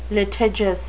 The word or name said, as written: litigious